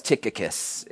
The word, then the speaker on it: Tychicus: Yes, Tychicus, if you were wondering how to pronounce that.